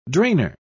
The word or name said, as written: drainer